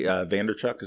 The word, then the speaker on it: Vaynerchuk: Gary Vaynerchuk, is that how you say it? – Yeah.